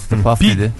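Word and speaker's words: P. Diddy: Puff Didi. – P Didi diye geçer, Puff Didi diye okunur.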